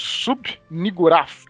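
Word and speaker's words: Shub-Niggurath: que é o Sub-Niggurath.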